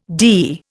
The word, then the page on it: dee: Indian